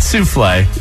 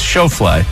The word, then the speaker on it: Schauffele: Is it like Schofield or something? – No. It's Xander Schauffele. – Is it Schauffele? – I think. – Souffle? – It's not Souffle. It's Schauffele.